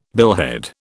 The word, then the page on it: billhead: British English